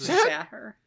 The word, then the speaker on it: Zahir: Whose name is Arif Zahir. I could be mispronouncing that, but I think it's Zahir. – Arif. Yeah, Arif Zahir.